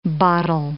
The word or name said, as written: bottle